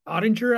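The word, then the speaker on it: Oettinger: Jake Ottinger, I think is how you pronounce his name. – Yep.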